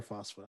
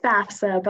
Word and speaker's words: FAFSA: Is it FAFSA or FOSFA? – We call it FAFSA, but I think you can pronounce it however you want.